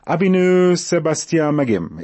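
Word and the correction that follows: Avinu Shebashamayim: Is that correct? The pronunciation? How's my pronunciation there? Did I get that? – Not exactly. – Okay. – It's Avinu Sebashamayim.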